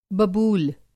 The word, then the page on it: बबूल: Hindi